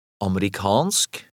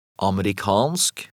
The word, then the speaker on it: amerikansk: In number 4, the stress is on the last syllable. Read number 4. – Amerikansk. Amerikansk.